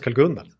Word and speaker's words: Karl-Gunnar: Asså, jag heter Jonathan Karl-Gunnar. – Nej, Karl-Gunnar. Har du ett dubbelnamn som andra namn? – Ja, det är med streck till och med, så det ska uttalas Karl-Gunnar.